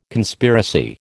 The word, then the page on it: Conspiracy: American English